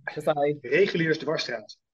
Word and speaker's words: Reguliersdwarsstraat: Reguliersdwarsstraat.